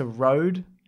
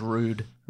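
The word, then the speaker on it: Røde: Thanks to Rode microphones and whatever that's called. – It's pronounced Rude. – Rude. – That's why the O's got the line through it.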